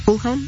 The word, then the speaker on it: Fulham: ¿Sí lo dije bien? Fulham, así se dice.